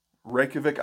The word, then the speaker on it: Reykjavik: Well, so during a four-day weekend, one of the 24th Marine Expeditionary Unit pulls into port at Reykjavik, Iceland. I hope I said that right, because that's how it's pronounced, yeah.